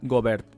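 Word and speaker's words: Gaubert: Eh, la obra, el compositor eh, se pronuncia Philippe Jobert, porque empezaba por G, y yo dije, Philippe Jobert, no me dice, no, no, Philippe Gobert.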